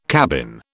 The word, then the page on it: cabin: British English